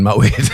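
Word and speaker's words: Moët: it's like you actually realise that Moet is the correct way to say it, like poet.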